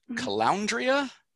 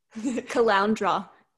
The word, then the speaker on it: Caloundra: Is it called the Caloundria? – Caloundra.